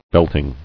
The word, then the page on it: Belting: belt·ing